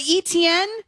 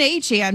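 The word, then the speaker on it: Achane: And so apparently, A-Chain, who was formerly known as ETN, says that it's always actually been A-Chain, but we've always been pronouncing it wrong with ETN.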